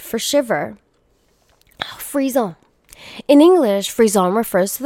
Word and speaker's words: frisson: Oh, Frisson. It's pronounced Frisson.